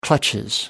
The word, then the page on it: clutches: British English